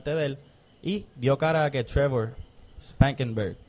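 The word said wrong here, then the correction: Spanberger: Spamberger. Spamberger. Es como una mezcla entre hamburger y spam.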